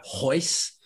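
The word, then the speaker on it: Royce: There are a lot of Gracies, and they all start with the name or the letter R almost, and none of them are pronounced like Roger. It's Hodger or Hoyce.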